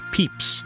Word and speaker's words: Pepys: That author's name is actually pronounced Pepys. – Interesting. – Not that interesting. You imagine like the Easter candy, but...